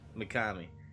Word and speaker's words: Mikami: Mikami? Am I saying this right? – That's correct. – Okay. Mikami. He wanted to go more of a action-packed game, and he wanted to steer away from survival horror, it sounded like.